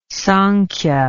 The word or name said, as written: samkhya